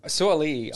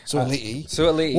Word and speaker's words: Sua'ali'i: Sua'ali'i. I believe. – Yeah, so. I think, because I pronounced it in a YouTube video and someone called me out for it. So Sua'ali'i.